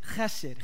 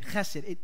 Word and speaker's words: chesed: That word loyal love in Hebrew, the word is chesed, chesed. It only works when you pronounce it, when you spit on someone.